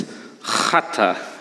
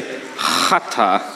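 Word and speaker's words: chata (חטא): Get ready how to pronounce it. It's chata. Everyone say chata.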